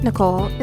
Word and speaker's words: Nichole: Also, dear listeners, my name, Nicole, is spelled NICHOLE.